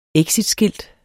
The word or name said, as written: exitskilt